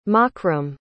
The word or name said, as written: makram